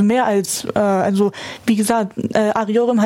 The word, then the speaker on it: Arıyorum: Arıyorum.